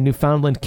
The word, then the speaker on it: Newfoundland: Newfoundland, Canada. – It's Newfoundland. – Newfoundland. I'm sorry. – No, Newfoundland. – Newfoundland. – No, not New Finland. Newfoundland.